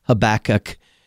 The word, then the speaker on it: Habakkuk: They knew this one, Habakkuk, because it sounds like tobacco or something like that.